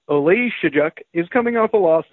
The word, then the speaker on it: Oleksiejczuk: We have Jimmy Crute, the plus 120, dog to Michael Oleksiejczuk. How did I do, Gumby? – Oh, Oleksiejczuk. Just the emphasis was wrong, but apart from that, you did well.